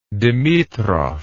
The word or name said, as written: dimitrov